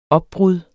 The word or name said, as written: opbrud